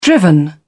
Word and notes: driven: British English

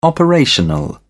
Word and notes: operational: British English

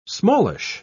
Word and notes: smallish: British English

